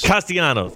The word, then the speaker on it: Castellanos: Am I saying that right? Castellanos? – No, Castellanos. – Castellanos.